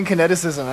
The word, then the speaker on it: Kineticism: I was saying kineticism, and I was pronouncing it perfectly. I was like, kineticism, with an echo, and I was doing it wrong. It's Kineticism, of course.